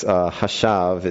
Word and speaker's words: hashav: That's hashav is how you say that Hebrew word.